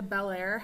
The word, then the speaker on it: Bellaire: So, that's what it sounds like, but it's not spelled like that. Like, it's one word. So, B-E-L-L-A-I-R-E. Bellaire.